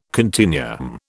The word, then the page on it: continuum: British English